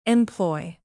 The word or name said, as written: employ